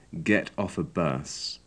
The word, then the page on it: get off a bus: English